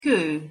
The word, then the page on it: ko: Hungarian